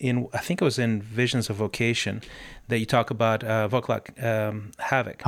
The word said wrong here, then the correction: Václav Havel: Am I pronouncing the Václav Havel.